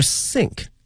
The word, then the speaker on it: sink: in their sink，水槽用的一个 sink，同学们注意啊，它的发音呢和我们说思考，我们想某件这个事情呢有一些接近，但是呢它的这个舌位是不一样的哈。这个思考或想某件事情的话是 think，是这个 think，需要一个咬住上下齿的这么一个发音，而这个是一个 sink，不需要咬的一个轻音哈。